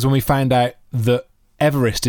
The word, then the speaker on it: Everest: But the way you pronounce that family surname is Everest.